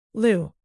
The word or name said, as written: lou